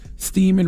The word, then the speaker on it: Steeman: What did Thomas Steeman, rhymes with semen, do?